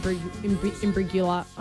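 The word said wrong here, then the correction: Imbruglia: Soft G. Soft G. – Imbrigula. – Soft G. – Imbrilia. – Bang. – Imbrulia. Natalie Imbrulia.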